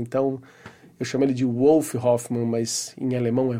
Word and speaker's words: Wolf: que é o Wolf Hoffmann, que inclusive me falaram que a pronúncia certa é Wolf porque ele é alemão. Mas aquela coisa, né, gente? É, a gente ouve a pronúncia das entrevistas, o cara tá nos Estados Unidos e fala um Wolf.